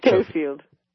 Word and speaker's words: Schofield: We're talking with Victoria Schofield. She's an expert on Schofield. – Schofield. Thank you very much. Did I say it right? – Yes, Schofield.